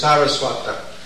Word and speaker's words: Saraswata: The word is S-A-R-A. The actual word is Saraswata. It's derived from Saraswati. Bhaktisiddhanta Saraswati. But this word is Sara with the long A, Saraswata, which means Prabhupada, the disciple of, offspring of, Bhaktisiddhanta Saraswati.